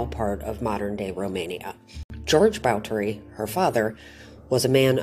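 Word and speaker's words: Báthory: You've probably heard it pronounced as Bathory. That's what most people say. But the proper period pronunciation is Bathory.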